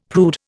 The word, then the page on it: prude: Indian